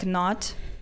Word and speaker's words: knot: You can't still don't pronounce that K, but it's still there. It's written in there. But it's an idea of like knotting things together, like knot.